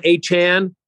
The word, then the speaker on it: Achane: So Devon Achane, that's how he likes his name to be pronounced.